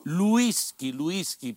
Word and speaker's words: whisky: Whisky, perché la pronuncia della W inglese in italiano è U e quindi si dice whisky.